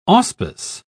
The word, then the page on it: auspice: British English